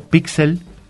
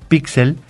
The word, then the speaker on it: píxel: Estos cuadritos que constituyen las fotografías, se puede decir tanto píxel como pixel. – Pixel. – De las dos maneras. – Okay. – Nada más que si lo decimos píxel, necesita el acento en la I.